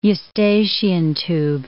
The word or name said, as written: eustachian tube